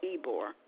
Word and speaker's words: Ybor: It's spelled with a Y, but pronounced Ybor. Y-B-O-R Street.